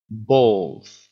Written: balls

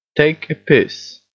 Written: take a piss

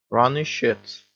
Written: runny shits

